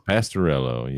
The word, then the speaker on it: Pastorello: Is that how you pronounce it, John? – Pastorello, yep.